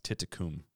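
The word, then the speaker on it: Thitikul: I also don't know how or why, but I believe Atia's last name is pronounced Titicum. – Titicum. – It finishes with an L, but I think it's pronounced with an M, and I cannot figure that one out.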